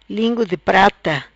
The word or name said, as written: Lingu Di Prata